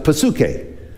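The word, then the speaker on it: psuche (ψυχή): In Greek, you would actually pronounce it psuche, but you would call it psyche.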